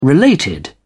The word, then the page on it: related: British English